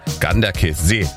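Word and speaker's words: Ganderkesee: Herzlichen Dank nochmal für die Aussprachetipps, die schnell reinkamen von unserem Radioarzt Jürgen in Gorch, der mir gleich geschrieben hat, Ganderkessee wird das ausgesprochen.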